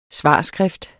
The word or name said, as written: svarskrift